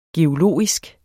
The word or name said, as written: geologisk